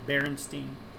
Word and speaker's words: Berenstain: It was never spelled B-E-R-E-N-S-T-E-I-N, Berenstain. It's spelled B-E-R-E-N-S-T-A-I-N, Berenstain Bears. And some people have, for their whole lives, always grown up as Berenstain, the Steen instead of Stain.